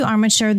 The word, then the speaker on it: Armature: Is it Armature? Is that how you pronounce it? – Yes. – Armature Work.